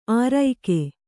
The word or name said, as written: ಆರೈಕೆ